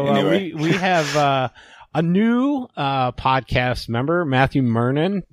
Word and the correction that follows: Mernan: It's actually Mernan, but it's a common mistake. I wouldn't worry about it. – I put the wrong emphases on the wrong syllable, which I've been known to.